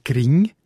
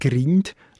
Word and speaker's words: Gring: Im grössten Teil vom Kanton Bern sagt man «Gring», nicht «Grind», also mit sogenanntem velarisiertem «nd» zu «ng».